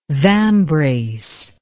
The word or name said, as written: vambrace